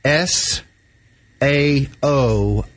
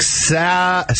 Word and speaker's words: Saoirse: And it was kind of catchy because her name is S. A-O-I-R-S-E. So it looks like Sa-O-R-S. So she said it's Saoirse. Rhymes with inertia.